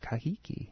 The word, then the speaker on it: Kahiki: Lono is one of the four major gods brought to Hawaii from Kahiki, or Tahiti in Hawaiian is pronounced Kahiki.